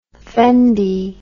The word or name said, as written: fendi